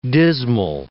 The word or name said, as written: dismal